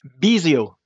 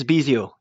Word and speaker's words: Bisiot: Bisiot, Patrice Bisiot.